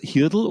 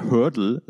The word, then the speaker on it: Hurdle: Also da bin ich mal, spricht man es Hirdl oder Hurdle aus? Also eigentlich muss es hier, was ich immer reimen soll, eigentlich muss es ja Hurdle sein.